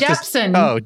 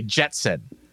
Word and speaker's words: Jepsen: Did I say her name wrong? I don't know. I just try not Jepsen. – To. Oh, I was just trying not to say Jetson.